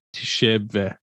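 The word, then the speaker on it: Tshiebwe: Kentucky'de 29 e, dakikada 19 rebound yapan, 7, 12 hücum reboundu yapan Şebve diye okunuyor. Şebve diye okunuyor yanlış bilmiyorsam. Şebbe gibi bir oyuncu olabiliyorken hani Koç Kalipani'nin altında.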